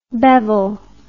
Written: bevil

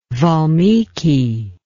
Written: Valmiki

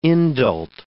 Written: indult

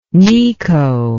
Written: necho

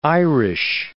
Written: Irish

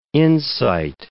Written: insight

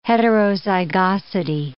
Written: heterozygosity

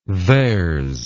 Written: theres

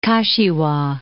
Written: Kashiwa

